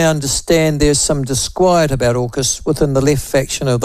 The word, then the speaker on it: AUKUS: We pronounce it AUKUS. – Oh, do you? AUKUS. Okay. – Yes, AUKUS.